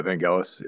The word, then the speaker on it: Vangelis: Yeah, Vangelis.